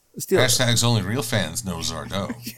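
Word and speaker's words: Sardo: Well, there's always Zardo, accent on the O.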